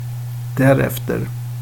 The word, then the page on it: därefter: svenska